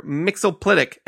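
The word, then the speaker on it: Mxyzptlk: If it's DC, it's Mr. Mixoplitic, and that is the official pronunciation from DC.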